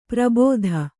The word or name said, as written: ಪರಿಭೇದ